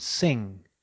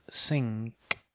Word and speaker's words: sing: You say king, not kink, and sing, not sink. Compare these two different sounds.